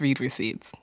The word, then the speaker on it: read receipts: So today we're launching a new segment called Red Receipts. – I want to see the receipts. – Wait, Madison, no, it's read receipts. It's pronounced read receipts.